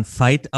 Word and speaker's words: Veit: Ich habe immer Veit Lindau gesagt, dann wurde in meiner Mastermind mir beigebracht, ich spricht man Fight aus, wie wurde das englische Wort Kampf, Fight, da, dazu nahegelegt.